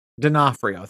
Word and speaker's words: D'Onofrio: D'Onofrio, thank you.